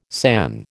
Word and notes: san: American English

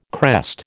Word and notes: crest: American English

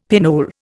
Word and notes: pinole: Indian

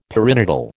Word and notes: perinatal: American English

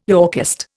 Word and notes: Yorkist: Indian

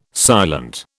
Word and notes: silent: British English

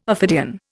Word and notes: ophidian: Indian

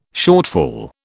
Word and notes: shortfall: British English

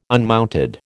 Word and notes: unmounted: American English

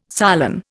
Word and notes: solen: Indian

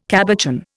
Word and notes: cabochon: Indian

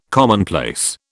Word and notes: commonplace: British English

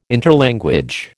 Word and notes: interlanguage: American English

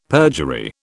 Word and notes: perjury: British English

